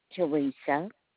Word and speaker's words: Teresa: Teresa. Do I need to spell it? – Yes. – T-E-R-E-S-A.